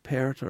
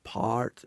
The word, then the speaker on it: Pärt: And then there's the so-called mystical minimalism, I guess you would say, that you see in the music of Arvo Pärt or Part, depending upon your pronunciation, or John Tavener or Goretzky.